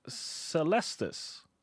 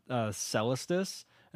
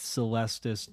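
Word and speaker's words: Celestus: Now, speaking of making it go day to night, the Celestis, 3-mana artifact. – Thank you. You pronounce it correctly because Saffron Olive pronounced it Celestis, and I'm like, it's Celestis, dude.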